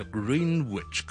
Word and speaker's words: Greenwich: Plymouth Plymouth Plymouth 那大家留意一下这个发音了再来一个格林威治或者格林威治 G-R-E-E-N-W-I-C-H 我们就不是读 Greenwich 的我哋叫 Greenwich，咁啊呢个亦都系一个几 counterintuitive 嘅，即系我哋就咁望落去估唔到系咁样读嘅。